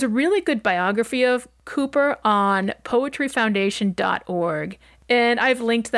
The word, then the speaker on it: Cowper: His name is spelled C-O-W-P-E-R and pronounced Cooper rather than, as I thought when I started writing this episode, Cowper.